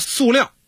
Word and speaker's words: 塑料: 刚才你说塑料啊，到底是什么读音呢？反正现代汉语词典还有普通话这个标准考试上都读塑啊，塑料。